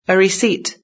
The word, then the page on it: receipt: British English